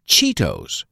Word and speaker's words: Cheetos: Nosotros pronunciamos chetos con una I larga. Decimos Cheetos.